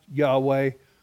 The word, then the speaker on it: Yahweh: YHWH. Pronounced Yahweh. We can pronounce it Jehovah in the English Bible.